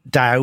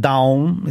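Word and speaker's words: Dão: I have a Daum. That's how you pronounce it. Not Dow, we would all say, but Daum is how you pronounce it.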